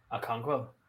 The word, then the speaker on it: Okonkwo: Okonkwo.